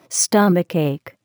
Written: stomachache

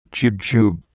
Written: jujube

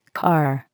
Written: car

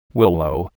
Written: willow

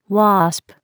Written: wasp

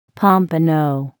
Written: pompano

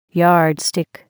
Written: yardstick

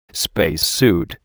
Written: spacesuit

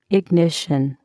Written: ignition

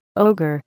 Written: ogre

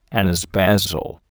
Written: anise basil

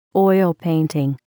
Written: oil painting

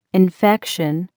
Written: infection

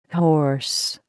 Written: horse

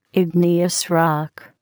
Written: igneous rock

